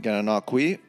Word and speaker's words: Gananoque: And the town's name, Gananoque, rhymes with a place called Cataraqui, which appears in the Cataraqui River or Cataraqui Creek.